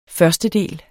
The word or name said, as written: førstedel